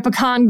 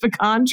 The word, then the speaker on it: pecan: Like, these are the neighborhood names because it's all just pecan trees. So I don't know where you were. There are other parts of the country that pronounce it pecan, but it ain't pecan mm-hmm.